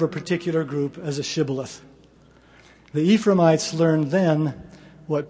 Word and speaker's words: Shibboleth: If they answered no, the Gileadites would ask them to say the word Shibboleth, a Hebrew word meaning stream. Ephraimites would pronounce the word with an S sound, Sibboleth, because they had difficulty pronouncing the SH sound.